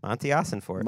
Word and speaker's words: Ossenfort: Colleen is Monty Ossenfort. – Okay. – Is that how you pronounce it? Mossy? – Monty Ossenfort. – Monty. – Monty. – You're good.